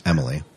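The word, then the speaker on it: Emilie: Sanctuary, um, Battlestar Galactica, and Smallville, and Reese, and I think it's pronounced Emily.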